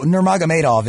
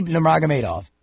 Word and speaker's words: Nurmagomedov: Oh, Nurmagomedov is how you pronounce it. Khabib Nurmagomedov.